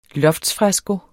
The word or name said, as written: loftsfresko